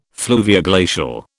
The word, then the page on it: fluvioglacial: British English